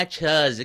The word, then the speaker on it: chuz: Ganun ang pag-pronounce niya na chuz. Kailangan yung labi niyo medyo bumababa ng very light.